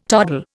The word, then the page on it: toddle: Indian